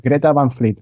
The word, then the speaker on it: Greta Van Fleet: en el primer, es Greta Van Fleet. O sea, creo que lo dije mal al principio.